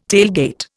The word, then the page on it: tailgate: Indian